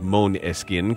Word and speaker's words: Måneskin: The unlikely global ascent of Måneskin, the word is Danish for moonlight, pronounced Måneskin,